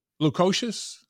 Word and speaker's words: Lukosius: And then CMOS Lukosha. Lukosius? – Close enough. Lukosius.